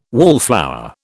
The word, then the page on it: wallflower: British English